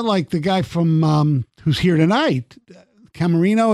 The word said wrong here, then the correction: Caminero: Caminero? – Yeah.